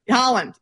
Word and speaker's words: Haaland: Maybe people are rooting for Norway because everyone loves Haaland. – Pronounce his name for me. Haaland.